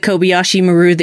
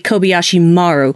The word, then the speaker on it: Kobayashi Maru: I figured it was probably a simulation, like the Kobayashi Maru that you didn't know was happening. So, right. – Excuse me, it should be actually Kobayashi Maru, which is the correct way to pronounce that.